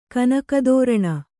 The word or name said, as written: ಕನಕದೋರಣ